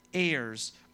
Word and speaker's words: heir: And it's spelled H-E-I-R. It's not like the air you breathe, that's not it.